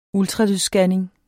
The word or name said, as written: ultralydsscanning